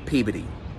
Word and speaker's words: Peabody: And sorry to do this to you, but this last one is pronounced Peabody.